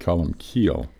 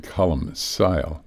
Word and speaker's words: Columcille: And so now we call it column keel rather than column sile.